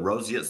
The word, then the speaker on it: roseate: Is it roseate? – Roseate. – Roseate. The roseate spoonbill. – Yes, exactly. We in the wildlife field name things for obvious reasons. And so it's named the roseate spoonbill because of its color.